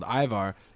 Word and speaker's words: Ajvar: And a very popular spread called Ivar. It's spelled A-J-V-A-R.